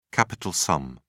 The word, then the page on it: capital sum: British English